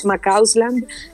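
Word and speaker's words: McCausland: Por favor, para que todos me sigan, arroba María Macausland,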